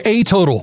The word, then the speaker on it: A Total: Just yodel for a total. They're fast, honest, and dependable. If you're having a total plumbing problem, they have a total solution. Remember, a total rhymes with yodel.